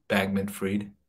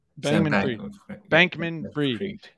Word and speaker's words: Bankman-Fried: Sam, can you pronounce his name? Sam Bankman-Fried. – Bankman-Fried. Yes.